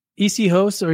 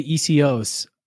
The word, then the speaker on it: Essihos: Well, it's you can say Essihos or you could say Essihos. I just do less hos and more os.